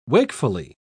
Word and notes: wakefully: British English

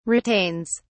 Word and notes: retains: British English

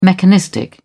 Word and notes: mechanistic: British English

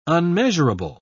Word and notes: unmeasurable: British English

